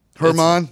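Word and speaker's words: Germán: The Rockies are starting German Marquez. – Herman. It's fine. It depends on where you're from. If you're from Indiana, it's German yeah.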